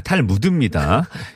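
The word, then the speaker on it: Talmud: 탈, 무, 탈모도 아니에요. 탈무드입니다.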